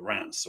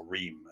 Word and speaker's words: Reims: up at Reims, or Reims as we would pronounce it, I suppose, but the French wouldn't. Where they were racing their sports cars in the 50s and stuff.